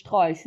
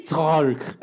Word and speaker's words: Strolch: I mean, I love how you pronounce Strolch. Say it one more time. – Strolch.